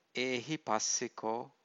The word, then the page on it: ehipassiko: Pali